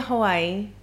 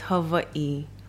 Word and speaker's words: Hawaii: Can say, people say Hawaii. – But it's not right. – The actual... correct pronunciation is Hawaii. – Hawaii. – All the W's Hawaii. Are just pronounced V.